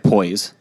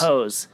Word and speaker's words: Poise: Yeah, they say Pose, but it looks like Poise. – Poise? – But they say Pose. It is Pose.